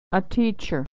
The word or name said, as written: a teacher